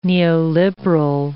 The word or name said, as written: neoliberal